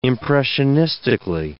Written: impressionistically

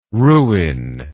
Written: Rouyn

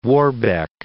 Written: Warbeck

